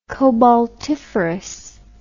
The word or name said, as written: cobaltiferous